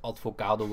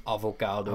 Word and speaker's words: avocado: en dat ik heel lang dacht dat het advocado was en niet avocado.